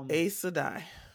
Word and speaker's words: Aes Sedai: When I saw it on the screen, I was like, yeah, I would never have said that that's how you pronounce Aes Sedai. A-E-S. – Mm-hmm. – It would definitely have been something like Ace. I probably would have said Sedai though, you know. Aes Sedai.